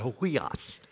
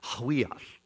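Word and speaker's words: huios: H-O-U-I-S in the English, but it's pronounced with a W. Huias. Huias.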